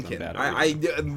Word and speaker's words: Galankin: Galankin. The worst part is, in my copy, I wrote it phonetically. It still can't pronounce it. Galankin. I wrote Gal, lawn, like your front yarn, lawnkin.